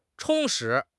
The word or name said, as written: 充实